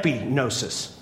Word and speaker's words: epignosis: It's not just gnosis, it's epignosis. Sorry, I pronounced the G, I shouldn't have. Epignosis.